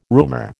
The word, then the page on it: roomer: American English